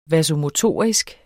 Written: vasomotorisk

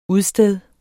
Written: udsteder

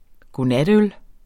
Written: godnatøl

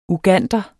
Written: Uganderne

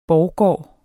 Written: borggård

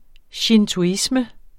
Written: shintoisme

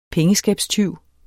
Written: pengeskabstyv

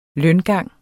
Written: løngang

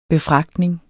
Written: befragtning

